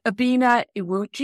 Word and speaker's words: Obinna Iwuji: And today we have the winner of this year's World Media Award, The Rising Star, and who is Obinna Iwuji. Yes. Now, have I pronounced that correctly? – Completely fine, Obinna, yeah.